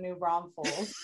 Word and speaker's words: New Braunfels: So they say New Braunfels. – Oh, so there's a whole YouTube like song about how there's no extra S in New Braunfels.